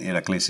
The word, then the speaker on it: Heracles: I'd see teams like Heracles, which is Hercules, but Heracles is how you pronounce it in Greek, and they're in the second division in Greece now.